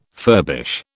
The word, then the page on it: furbish: British English